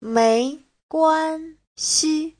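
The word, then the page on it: 没关系: mei2guan1xi5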